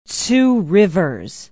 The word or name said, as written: two rivers